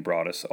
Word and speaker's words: Broaddus: Um, I understand what that's like because people butcher the name Broaddus all the time.